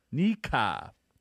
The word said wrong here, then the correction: Nikka: is what it's called. Nikka. – You nailed it.